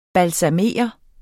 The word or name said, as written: BALSAMERE